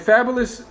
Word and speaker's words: Fabolous: And what bothered me about that was, and Fabulous, F-A-B-O-L-O, I got to say it like the record. – F-A-B-O-L-O-U-S.